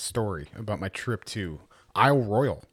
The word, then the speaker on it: Isle Royale: So, but yeah, the pronunciation is Isle Royale. I'd like me and Joe and I were calling it Isle Royale, like, you know, Battle Royale, but, that's like a mixture of two different types of pronunciations.